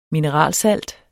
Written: mineralsalt

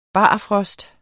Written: barfrost

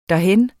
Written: derhen

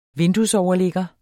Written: vinduesoverligger

